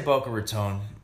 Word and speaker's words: Boca Raton: Yeah, I'm doing a show up in Boca Raton on the 25th. – Is it Raton or how do you say that? – I say Boca Raton.